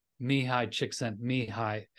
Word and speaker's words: Csikszentmihalyi: I've heard it pronounced. I think it might have even been the guy he worked pretty closely with, Mihai Csikszentmihalyi, is how he said it.